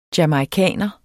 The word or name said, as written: jamaicaner